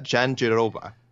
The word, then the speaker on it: Jandiroba: Like Verna, I'm going to butcher this last name, by the way, only because I was studying trying to how to pronounce it, and I've seen it pronounced like multiple ways, but I will go with how I heard the wonderful Joe Martinez, I think this is how he pronounced it, Verna Jandiroba, who just trucked Felice Herrig.